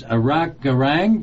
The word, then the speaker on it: Arok Garang: My guest is Arok Garang.